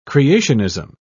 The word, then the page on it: creationism: British English